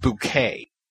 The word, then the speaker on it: Bucket: And she married a man named Richard Bucket, B-U-C-K-E-T, but she decides we're pronouncing it Bouquet.